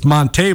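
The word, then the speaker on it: Montee: He tells the guys, oh, by the way, it's Monte Ball. I have a little, you know, accent on there. Like, my mother named me Monte. My name's not Monty. I'm not a Monty. My name's Monte.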